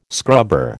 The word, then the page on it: scrubber: American English